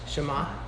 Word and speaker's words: Shema: Shema.